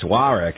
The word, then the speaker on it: Warwick: That's my American, boring, dull, everyday, generic English pronunciation of it. It's Warwick, as he would say it.